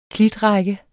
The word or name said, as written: klitrække